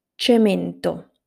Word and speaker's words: cemento: cemento, centro, cesto. I'll repeat them again. Cemento, centro, cesto. To sum up, if the letter C is followed by I or E, it is pronounced as a soft sound.